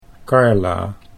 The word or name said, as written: Carla